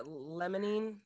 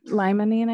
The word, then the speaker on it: Limonene: Mycerine and what is it? Limonene? – Limonene, I think is how you say it.